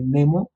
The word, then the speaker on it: Nemo: o sea, Nemo él lo tomó del latín, eso sí es así, se, como se escribe, así se lee Nemo, que, pues significa ninguno o, nadie.